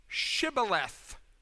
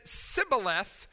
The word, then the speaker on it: Shibboleth: And this is the famous story where, to determine who is an Ephraimite and who is not, in verse 6 they said, then say Shibboleth. And when he said Sibboleth, for he couldn't pronounce it right, they seized him and slaughtered him. Apparently the Ephraimites had a speech limitation. They could not say the sh sound. They could only say the s sound.